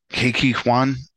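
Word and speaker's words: Ke Huy Quan: KK Kwan, am I saying it right? – Yes.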